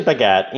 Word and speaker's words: Bhagat: It's actually Bhagat. You know, there's an Indian way to say it, and there's a English American way to say it, which is Bhagat.